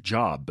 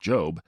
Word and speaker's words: Job: It's spelled like Job, but it's pronounced Job,